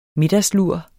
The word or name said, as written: middagslur